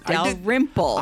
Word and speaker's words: Dalrymple: No, Dalrymple.